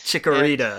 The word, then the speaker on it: Chikorita: Chiquita, I believe, is the name. Yeah, that's just what I call it because I think of Chiquita the banana. – Chikorita. And yeah, there's Chikorita, another syllable in there.